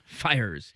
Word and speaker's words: Fiers: I think Fiers is how you say his last name. Is that how you say it? – Is it Freers? – Fiers.